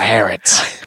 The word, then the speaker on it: Harrods: In Trafalgar Square, you got Harrods.